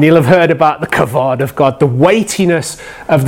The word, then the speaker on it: kavod: But the word there for honour and honouring is this wonderful word, kavod. So say it with me. You have to say it like a big rabbi with a huge beard and a big hat.